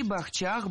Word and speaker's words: бахчах: Маргарита сказала все правильно. Действительно, бахчи, бахчах, бахчами, бахчей. Вот только ведущая немного обсчиталась. Ударение во всех формах падает не на третий, а на второй слог.